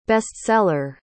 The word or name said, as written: bestseller